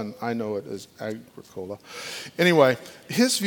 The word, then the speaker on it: Agricola: Agro, ag, how would you pronounce Agricola? Agricola. That was the other one I was going to go with.